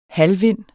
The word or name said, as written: halvvind